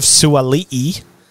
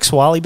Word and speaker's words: Suali'i: Daniel Tupou and Joseph Suali'i. Yes, that's how it's pronounced, Suali'i, which is Mm. Different. I like Swali better, but Suali'i.